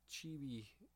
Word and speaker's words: chibi: it just seems like, don't, I don't know, maybe it's just the chibi.